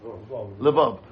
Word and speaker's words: Lvov: Lvov.